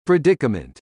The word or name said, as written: Predicament